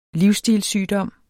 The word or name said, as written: livsstilssygdom